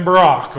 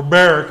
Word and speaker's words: Barak: And so we find the story of not just Deborah, but Deborah and Barak, or Barak, or however you want to say it, right? Depends on where you're from, how you say it. All right, so Barak.